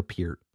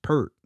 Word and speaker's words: Peart: That's what guys that are like really like Rush heads are like Neil Peart. Like, shut the fuck up. It's Pert. – It's Neil Pert.